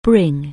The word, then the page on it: bring: anglais